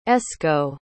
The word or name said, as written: esco